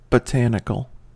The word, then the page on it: botanical: American English